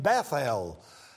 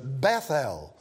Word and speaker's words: Bethel: It is the name Bethel, as we might rightly pronounce it. Bethel, as verse 19 shows to us, and it says there he called the name of that place Bethel.